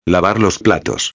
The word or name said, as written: lavar los platos